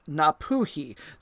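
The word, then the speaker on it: Ngāpuhi: She is of Maori descent from a nation, or iwi, in the northern part of the island called Napuhi. There's a G in there, but I think the G is silent, and I got to learn that the word iwi, I-W-I, means nation or tribe for the Maori.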